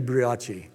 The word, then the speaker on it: brioche: That's the buachi. That's the buachi bread in the Middle East. Did I say that right, the buachi? Did I say buachi right? Huh? Huh? Brioche? It should be brioche.